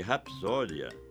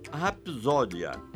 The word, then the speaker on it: rapsódia: Já no caso de rapsódia, o que se tem é um fonema surdo, o P de pato, seguido de outro também surdo, de S. Aqui, ninguém influencia ninguém. Apesar de tudo isso, é inegável que existe uma tendência, eu disse tendência apenas, para pronunciar rapsódia, com Z de zero.